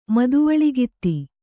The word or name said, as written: ಮದವಳಗಿತ್ತಿ